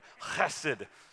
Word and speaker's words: Chesed: Chesed. That's how you got to say it in the Hebrew. All right, you got to cough something up a little bit right there. Chesed.